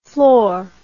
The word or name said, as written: flor